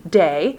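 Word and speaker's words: day (δέ): But there is a term there, and we would spell it phonetically, D-E, day.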